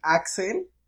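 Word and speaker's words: Acel: Eh, pues yo soy Axel, con doble C, no con X, como todos piensan. De hecho, creo que mi nombre se pronuncia como Axel, no Axel.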